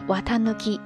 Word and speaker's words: 四月一日: 那刚才也提到了四月朔日就是四月一日的意思，你别说，除了四月朔日，还真的有四月一日这个姓呢，也读作 watanuki。